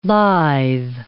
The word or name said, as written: lithe